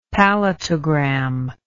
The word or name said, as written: palatogram